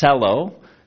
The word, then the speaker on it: Xello: Its name is Cello, is how it's pronounced. It's spelled X-E-L-L-O.